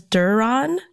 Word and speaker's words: Doron: Is that correct? – Yes, that's how I pronounce it. I think my dad says Doron.